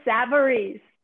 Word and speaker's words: Savarese: Mary Kay Savarese.